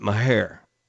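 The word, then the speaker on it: Mexia: But it's also, and I'm not kidding you, if you lived anywhere around Waco like I did in the early 1980s, mid-1980s, a lot of people pronounce it Mahair.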